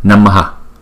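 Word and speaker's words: namaḥ: Thì cái dấu đó cho chúng ta biết là chúng ta phải lấy cái nguyên âm này chúng ta choàng ra ngoài sau, chúng ta đọc là Namaha, không?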